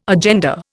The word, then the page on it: agenda: Indian